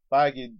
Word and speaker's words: Feige: Feige decided we're going to hold off for the Inhumans, right? – Okay.